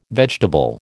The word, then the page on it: vegetable: American English